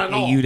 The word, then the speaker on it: Renault: Did Renault. – Say Renault. Sorry. – Renault. – It's French. I don't care. – But so then at the same time, pronounced Renault.